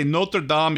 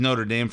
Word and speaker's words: Notre Dame: I say Notre Dame because that's how they say it in France. It's Notre Dame for those of us in America, but this is the cathedral, not the college.